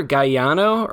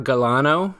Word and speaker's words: Galeano: It's either Galeano or Galano. Last name is spelled the G-A-L-E-A-N-O.